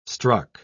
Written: struck

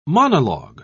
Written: monologue

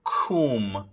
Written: cwm

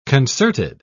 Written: concerted

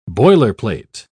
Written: boilerplate

